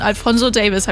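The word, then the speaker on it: Alfonso Davies: In ihrer Story bedankt sie sich beim FC Bayern-Spieler wie Luis Diaz, Harry Kane, Jamal Musiala und Alfonso Davis. Habe ich alles richtig ausgesprochen, oder? – Ja, korrekt.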